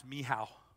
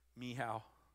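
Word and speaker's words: Michal: So what about Mikhail? That's pronounced Mihal, by the way. Mihal.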